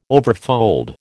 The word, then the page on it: overfold: American English